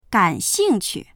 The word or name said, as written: gan3xing4qu